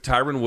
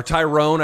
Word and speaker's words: Tyron: And they had a press conference yesterday in LA with Tyron Woodley. Everyone couldn't get his name pronounced correctly. It's Tyron. – You mean Tyron? Tyron was there, right? – Look. Yeah, or Tyrone, I think, as Jake called him.